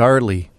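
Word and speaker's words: R. Lee: The way you say it, it's an initial, isn't it? R? – R. It's the letter R. – Right. But when you say it, you say it like it's Arlie. – Arlie Ermey. It's R period Lee? – Yeah.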